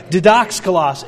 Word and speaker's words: didaskalos: Okay, the word masters here is translated from the Greek word didaskalos. Aren't you proud of me? I could say that right. Didaskalos.